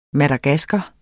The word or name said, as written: madagasker